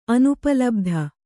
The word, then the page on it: ಅನುಪಲಬ್ಧಿ: anupalabdhi